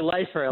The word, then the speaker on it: Leifer: It's actually Leifer, like life in prison.